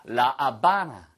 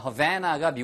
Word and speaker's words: La Habana: 아, La Habana. 예, 하바나라고 하잖아요. Havana가 미국식 발음이고요. La Habana, 이게 바로 스페니쉬식 발음인데 정말 예쁜 도시입니다.